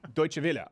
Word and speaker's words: Deutsche Welle: How do you pronounce this, Sven Computer? – Dortmund? – No. – Deutsche Welle? – Deutsche Welle. Okay, yes. From Deutsche Welle.